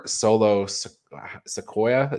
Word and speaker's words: Solo Sikoa: They also filed for a trademark, speaking of the bloodline, for Solo Sikoa. Is that how we say his name now? – Yes.